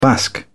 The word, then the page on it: basque: British English